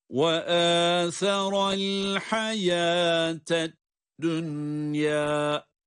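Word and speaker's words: dünyâ (دنيا): Bunlarda idgam-ı ma'al gunne olmaz. Bunlar izhar ile okunur. Çünkü burada cezimli nun ile ya harfi aynı kelimede gelmiştir. Bu tür yerlerde okuma izhar iledir. Yani ed-dünya şeklindedir.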